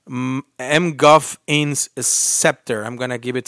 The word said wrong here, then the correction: MacGuffin's: So I think they're trying to say MacGuffin's Scepter.